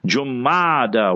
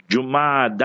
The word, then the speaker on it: Jumada: See how many ulama pronounce it wrong. Jumada with a dhamma, pesh on the jim. Jumada.